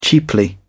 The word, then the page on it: cheaply: British English